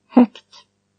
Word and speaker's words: högt: This change also happens before T. G is pronounced K. – Högt.